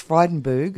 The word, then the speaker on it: Frydenberg: and Josh Frydenberg, who, that's how you say it, isn't it? That's right, he is our Minister for Energy and, no, Environment and Energy.